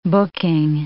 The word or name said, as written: booking